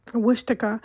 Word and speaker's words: Wichita: Wichita.